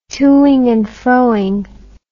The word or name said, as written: toing and froing